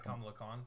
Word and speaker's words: Kamala Khan: Is it Kamala Khan? – Yeah.